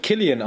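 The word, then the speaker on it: Cillian: Es wird Killian ausgesprochen. – Ach echt, ja? – Äh, ich konnte es nicht glauben, aber es ist so. Obwohl es mit C geschrieben wird, ah, ja.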